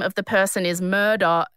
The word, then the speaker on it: Murdaugh: And it's pronounced Murdaugh. I looked it up.